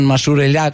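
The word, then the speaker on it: Mashrou' Leila: Di Mashrou Leila. – Mashrou Leila. – Mashrou Leila, sì. L'ho detto bene. Sì.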